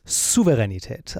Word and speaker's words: Souveränität: Souveränität. Also ganz wichtig mit einem scharfen S am Anfang. Also Souveränität ist halt falsch.